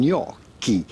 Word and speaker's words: gnocchi: We are looking at how to pronounce with a typical Italian pronunciation, gnocchi. Yes, double C-H in Italian sound like the letter K, so just replace those letters with a K and it'll make a lot more sense. Gnocchi.